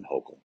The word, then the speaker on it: Hochul: Hochul, I call her Hochul. Some people call her Hochul. I think she pronounces her name Hochul.